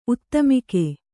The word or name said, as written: ಉತ್ತಮಿಕೆ